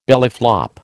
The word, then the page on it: bellyflop: American English